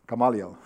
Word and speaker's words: Gamaliel: Gamaliel, yeah.